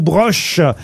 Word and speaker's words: Broch: Alors, je sais pas si on prononce broer ou broche. – Broche. – Parce que parfois, ça dépend s'il y a un umlaut sur le O.